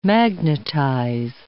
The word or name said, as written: magnetize